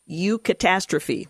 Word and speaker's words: eucatastrophe: He called it the, eucatastrophe.